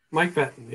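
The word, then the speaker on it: Mike Pettine: I mean, Mark Petten, right? Is that how you say his name? Mike Petten? – Mike Petten, yeah.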